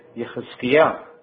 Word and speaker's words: Yechezkiah: But Yah in Hebrew, when it comes in the beginning, is never Yah. It is changed to Yeh. But if it comes as a second syllable or the end of the word, then you can say Yah, like Jeremiah, or in Hebrew, Yirmiyah, or Hezekiah, or in Hebrew, Yechezkiah. You see, Yah will come at the end, but when Yah comes in the beginning, it changes to Yeh.